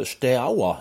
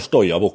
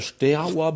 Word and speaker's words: Steaua: Jeg har alltid sagt bare 'Stoja men det er 'Steaua Bucuresti'.